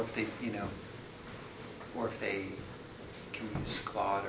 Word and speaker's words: Claude: Claude, it's like C-L-A-U-D-E. – Like the name. – Like the name. – Not like cat claws.